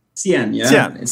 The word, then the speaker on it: Cien: So you're CEO, founder of, and you pronounce it Cien? – Cien. – C-I-E-N. – And Cien means 100 in Spanish.